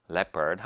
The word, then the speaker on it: leopard: 자, leopard라고 할때이 단어를 leopard라고 읽거나 혹은 다르게 읽는 친구들이 있는데요. leopard입니다. leopard.